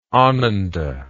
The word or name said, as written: ananda